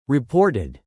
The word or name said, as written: reported